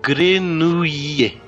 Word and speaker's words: Grenouille: A pronúncia seria mais ou menos assim. Grenouille. Grenouille. – Grenouille. – Um I comprido entre o U e o O. Grenouille. Se puder fazer um R com a garganta, Grenouille. Grenouille, como os cariocas.